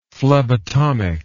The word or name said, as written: phlebotomic